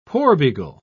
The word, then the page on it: porbeagle: British English